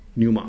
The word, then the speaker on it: pneuma: 那这个希腊文叫做 pneuma 啊，p 不发音，pneuma 啊。